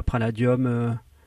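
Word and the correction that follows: Präludium: Ah non, c'est très simple. C'est très simplement Préludium, ça veut dire prélude.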